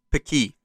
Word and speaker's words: Paqui: How do you say this company? – Pakui? – P-A-Q-U-I. – Pakui.